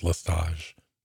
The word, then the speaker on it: Lestage: And I was told too on our YouTube channel that the T in his last name is not pronounced. I don't know. I've heard it just Lestage, but, we'll find out long-term.